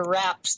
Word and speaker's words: Wrawps: I'm looking at some of your wraps that you call, I don't even know how to pronounce it, W-R-A-W-P-S. – Wraps. Yeah, that's actually a brand, and they make raw wraps that are really good and oil-free, and I love to use them.